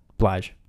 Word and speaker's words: Blige: Did I say the name right? Or is it Bly? – Yes. Blige.